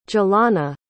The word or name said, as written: jalana